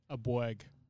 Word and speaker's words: Abueg: Abueg.